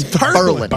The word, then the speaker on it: Berlin: How do you think you pronounce that? – Berlin. – Berlin? – Berlin. It's Berlin.